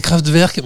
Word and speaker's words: Kraftwerk: C'est Kraftwerk, mais, mais c'est génial.